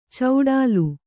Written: ಚೌಡಾಲು

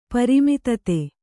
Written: ಪ್ರಮೋದೂತ

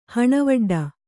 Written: ಹಣವಡ್ಡ

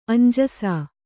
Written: ಅಂಜಿಸು

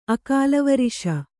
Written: ಅಕಾಲವರಿಷ

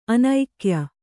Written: ಅನೈಕ್ಯ

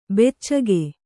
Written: ಬಚ್ಚೆಗ

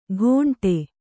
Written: ಘೋಂಟೆ

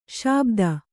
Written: ಶಾಬ್ದ

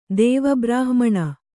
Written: ದೇವಬ್ರಾಹ್ಮಣ

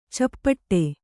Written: ಚಪ್ಪಟ್ಟೆ

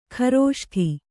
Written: ಘೃಷ್ಟಿ

